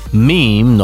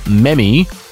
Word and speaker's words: meme: If you have problems working out how to say things, like say, meme, not memmy, or SEO, not CO, Google is rolling out a pronunciation feature.